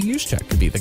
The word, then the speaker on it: Juszczyk: No one can say his name, by the way. It's Juszczyk. – Juszczyk. Yeah, Juszczyk. Sorry. Juszczyk. Juice. The juice. The juice is loose.